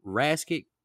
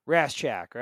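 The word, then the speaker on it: Rasczak: Is it Raskic? – Raschak, right? – Raschak? Okay. – Yeah. – Just wanted to get that right. The new leader walks in. It's a teacher, Raschak.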